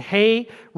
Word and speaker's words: He: Not he, but hey.